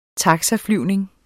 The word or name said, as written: taxaflyvning